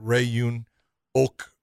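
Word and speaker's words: Ok Rae-yoon: if you're going to look at Ray-Yoon Ok, is how you say it, it's okay, but this is the guy that beat Eddie Alvarez in his last fight, and he hurt Eddie in that fight.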